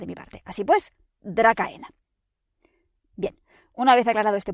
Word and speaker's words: Dracaena: Y es que, quizás era incorrecto, no he logrado aclararlo del todo, pero me niego a pronunciar el nombre científico de los árboles dragón originarios como Dracaena o Dracaena, como hacen los ingleses. O sea, no. Su nombre se escribe Dracaena. Y o lo voy a pronunciar así o a malas, Dracaena. Porque la raíz etimológica de su nombre está en la palabra griega dracón. Y no voy a quitarle esa K tan dracónica y condenarla al ceceo.